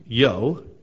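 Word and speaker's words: Io: The customary greeting for the occasion is I-O, pronounced yo.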